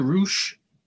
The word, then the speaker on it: Reusch: It's just Mr. Roosh. I spell it all out, M-I-S-T-E-R, and then my last name, R-E-U-S-C-H.